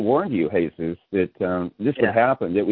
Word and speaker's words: Jesus: Is it a Y sound at the beginning? Or H? – It's an H. H. – H. Jesus. – In English, Jesus, yeah.